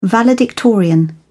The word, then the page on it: valedictorian: British English